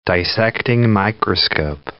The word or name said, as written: dissecting microscope